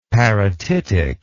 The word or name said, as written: parotitic